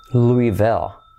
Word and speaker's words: Louisville: Se pronuncia Louisville. Letra clave L, Louisville.